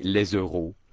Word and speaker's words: euros: In France, it's spelt E-U-R-O-S, but the French, not pronouncing the S at the end of the word, simply will say les euros.